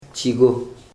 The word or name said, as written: chi.go